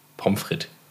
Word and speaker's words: Pommes frites: Pommes frites. Betonung auf dem ersten O.